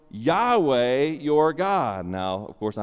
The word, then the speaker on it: Yahweh: And if you transliterate that name, if you look at the Hebrew and just pronounce the Hebrew, you might pronounce that name Yahweh.